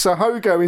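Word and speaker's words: haut-goût: So Hogo is literally H-O-G-O, whereas the French term is H-A-U-T-G-O-U-T. – Oh, okay. – So I think they pronounce the same, which translated means high taste, and high taste refers to a kind of gaminess, just on the turn of being rotten.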